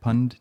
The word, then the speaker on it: Pundt: I think that's how you say it, Pund? – I think so, yeah. – P-U-N-D-T.